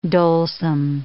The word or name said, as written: dolesome